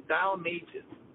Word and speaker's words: Balmages: So the best way to say it is Bal like pal and Mages like pages. So if you say pal pages, then Balmages.